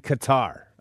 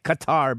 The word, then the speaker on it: Qatar: I like Qatar because it doesn't sound, it sounds more exotic, Qatar, instead of gutter, which that's how the Arabs pronounce it, gutter.